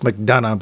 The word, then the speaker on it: McDonagh: I think it's just McDonagh, but there you go. – Yeah, it's a Celtic last name, so sometimes you pronounce the last two.